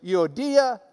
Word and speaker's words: Euodia: But if you've got the Greek reading next to it, you might as well pronounce it the way they do. So it is Euodia and Syntyche.